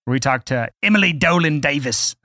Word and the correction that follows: Davies: Pretty sure you just pronounced it wrong. – Davies.